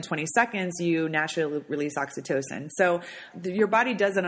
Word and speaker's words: Oxytocin: Oxytocin, yeah.